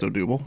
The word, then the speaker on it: Odubel: Is it Odubel? How do you pronounce that? Herrera's first name. – I believe it's Odubel.